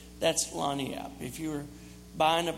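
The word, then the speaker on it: lagniappe: Okay, here's the word, Lagniap. Can you say that? Lagniap. Look it up. It's spelled like you would never believe. It's got G's and N's and all kind of French spellings in it.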